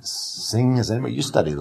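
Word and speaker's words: Hsing: H-S-I-N-G, I think it's the, Xing. – Yeah, Xing, Sing.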